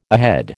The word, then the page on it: ahead: American English